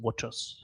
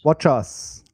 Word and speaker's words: watchOS: Korrektur, die richtige Aussprache ist WatchOS. – WatchOS.